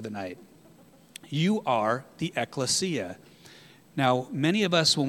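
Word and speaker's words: Ekklesia (ἐκκλησία): Excuse me, I'm saying it wrong. Ekklesia. In the Greek it's, say it with me guys, help me out. Ekklesia. Ekklesia. Okay, not ecclesia, it's ekklesia. Just like it's not exousia, it's exousia.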